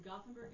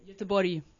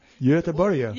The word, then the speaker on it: Göteborg: Well, Gothenburg is the English version of it. – How do you say it? – Göteborg. Yeah, try to say that. Göteborg. – Göteborg.